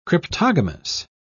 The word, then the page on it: cryptogamous: British English